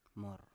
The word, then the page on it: mor: Biak